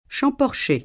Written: Champorcher

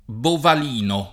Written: Bovalino

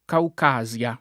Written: Caucasia

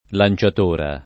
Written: lanciatoia

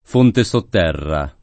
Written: Fonte Sotterra

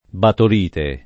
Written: batolite

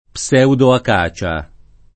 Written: pseudoacacia

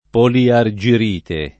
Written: poliargirite

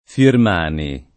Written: Firmani

